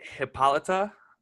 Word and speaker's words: Hippolyta: So mine here is Hippolyta. Because I just kudos on that pronunciation, man. You nailed it.